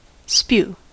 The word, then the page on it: spew: American English